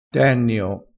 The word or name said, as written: tennio